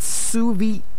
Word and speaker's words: souvenir: All right, and then we have another word, and that's as we go out to parties today, we know that it's souvenir, not souvenir. No, souvenir. It's souvenir. Souvenir, good. That's what it's pronounced as O-U-V-E-N-I-R, not souvenir, souvenir, whatever you however you haven't pronounced it, it's time to change it. It's souvenir. That's the way it's pronounced.